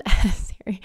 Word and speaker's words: Sari: A lot of people pronounce it sorry. It looks kind of like S-A-R-I. Looks like it might be pronounced with the, what is it, short vowel sound? Sari. And Sari is how you pronounce it.